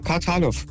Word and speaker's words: Kartalow: Ja, Kartalow. K-A-R-T-A-L-O-W.